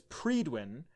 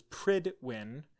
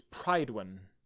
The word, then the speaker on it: Prydwen: The vessel's name is pronounced differently by certain characters. Military frequency AF95 broadcasts it as Predwin, whereas Paladin Danse, Lance Captain Kells, and most other characters pronounce it as Pridwin. The Welsh pronunciation is Pridwin.